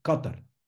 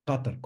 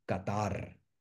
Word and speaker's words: Qatar: Nosotros le hacemos una palabra grave, pero en realidad es una palabra aguda en árabe. Ellos lo pronuncian como Qatar, Qatar, con unas as muy cortas, y nosotros le damos Qatar.